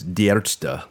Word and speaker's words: Die Ärzte: If you mention the band name, and I always butcher it, but it's Die Ärzte, which means the doctors.